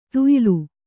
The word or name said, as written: ತೇಯೆಲೆ